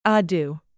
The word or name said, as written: ado